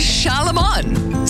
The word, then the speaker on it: Charlemagne: It's Charlemagne.